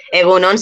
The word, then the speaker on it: egun on: Egunon sí que sé cómo se escribe. Egunon. Egunon. – ¿Cómo se escribe? – A ver. Eso sí. E-G-U-N-O-N.